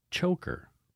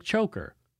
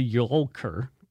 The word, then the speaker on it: Tjoelker: It's Megan Choker, but it's spelled T-J-O-E-L-K-E-R. So she said, it's pronounced like choker. So thank goodness it's pronounced like choker, because if I had to say to yolker, I would sound like a boob, even though I just did.